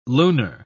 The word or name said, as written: lunar